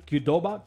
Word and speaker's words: Qdoba: Qdoba.